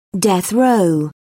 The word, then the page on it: death row: British English